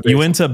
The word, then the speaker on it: Uinta: I'm not going to say it wrong again, you need a basin. I'm saying it wrong, I'm sure. – Uinta Basin.